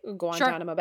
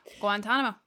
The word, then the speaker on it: Guantanamo: Guantanamo Bay. – Guantanamo.